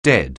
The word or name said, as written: dead